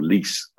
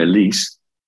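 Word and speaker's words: Luus: And Suna Lucy's captaincy, or Lise, as it turns out, that it should actually be pronounced. Two U's make an E in Afrikaans. So Suna Lise, as captain, I'm afraid just hasn't cut the mustard.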